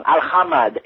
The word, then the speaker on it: Al-Hamad: That's Al-Hamed. The other Al-Hamed, you're spelling his name wrong. Al-Hamad, A-H-Flem-M-A-D. Hamad. – Okay. – Al-Hamed. Yeah. – I stand Al-Hamad. Correct.